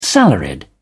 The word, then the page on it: salaried: British English